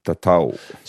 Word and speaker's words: tatau: Tatau.